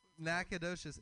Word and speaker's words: Nacogdoches: Nacogdoches. It's spelled Nac-og. Doches.